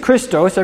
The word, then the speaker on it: Christos: So we're going to look at a couple places where this verb, Christos or Mashiach, turns up in the Bible that Jesus read. This verb, okay, the Greek is Christos.